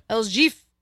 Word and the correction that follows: Jefe: Am I pronouncing that right? El Jefe? – Jefe. – Jefe. El Jeef.